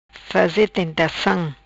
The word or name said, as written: faze tentacam